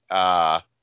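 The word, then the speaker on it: Tartakovsky: It's a fantasy prehistoric series created by Genndy Tarkovsky.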